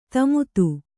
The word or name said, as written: ದಮಥ